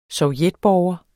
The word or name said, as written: sovjetborger